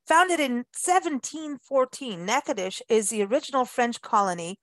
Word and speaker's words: Natchitoches: And so, of course, whenever we talk about Natchitoches, by the way, uh, it is spelled N-A-T-C-H-I-T-O-C-H-E-S, but pronounced Natchitoches.